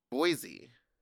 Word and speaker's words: Boise: How do you say it? – Boise. – They don't do the Z over there.